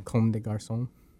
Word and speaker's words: Comme des Garçons: Comme des Garçons.